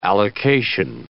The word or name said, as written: allocation